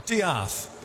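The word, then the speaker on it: D'eath: Oh, I was wrong. – Diaeth. – Diaeth. – Isn't that much nicer? – Yeah, it's spelled death with a apostrophe in it.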